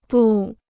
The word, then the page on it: 不: bù